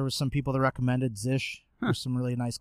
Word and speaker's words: zsh: I'm using, I don't know how you say it, it's Zish, Z-S-H.